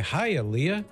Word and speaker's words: Hialeah: So if you know a girl named Leah, you'd say, Hialeah.